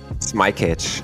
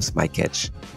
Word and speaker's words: Smykaz: Smykaz. – Smykaz. – Yeah, I think even in Germany, everybody has the problem. So, but I think this is the English way I pronounce my, introduce myself, Smykaz.